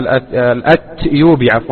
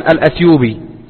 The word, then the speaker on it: الأتيوبي: ومن طرائف ما يروى أن شيخنا الشيخ العلامة محمد بن علي آدم الأتيوبي الأتيوبي يكتب في كتاباته وفي كتبه بالتاء، بينما السائد في النطق أن الناس يقولون الأثيوبي له ولغيره.